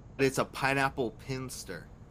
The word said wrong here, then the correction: Pilsner: Like, how do you say it? – Pinsters? – Is that what it is? Pinsters? It's a type of beer. – Pilsner? – Pilsner.